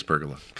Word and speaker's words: pergola: So wait, what's the proper way to pronounce it? – I think it's pergola. – Pergola. It is pergola.